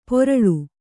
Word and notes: ಪೊರಳು: poraḷu

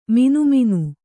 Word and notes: ಮಿನಿಮಿನಿ: mini mini

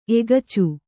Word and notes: ಎಗಚಿ: egaci

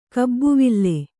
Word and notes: ಕಬ್ಬುವಿಲ್: kabbuvil

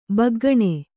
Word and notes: ಭೊಗ್ಗನೆ: bhoggane

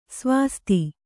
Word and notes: ಸ್ವಾಸ್ತಿ: svāsti

